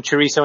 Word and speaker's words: chorizo: Did you manage to get some chorizo on your pizza this week? – I did.